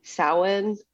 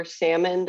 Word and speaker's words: Samhain: I think there's different pronunciations, but I know one of them is Samhain or Salmon, depending. Yeah, it depends on where you're from, how you say it.